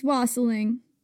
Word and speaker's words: wassailing: It's wassailing. Rhymes with fossiling.